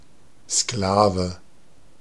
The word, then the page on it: Sklave: /ˈsklaːvə/